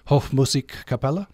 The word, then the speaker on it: Hofmusikkapelle: Christoph Campestrini from Austria, and you're associated with Vienna Hofmusikkapelle? – Yes, it's true. – Did I get close on that? – Yeah, excellent. – Okay. – Perfect pronunciation.